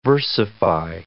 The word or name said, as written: versify